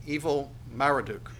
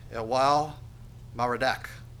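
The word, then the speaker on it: Evil-Merodach: His name being, if I were to pronounce it phonetically, it would look like Evil Marduk, but it's probably pronounced Elwal Marduk.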